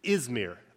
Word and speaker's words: Izmir: It is today the Turkish city Izmir, I-Z-M-I-R, Izmir.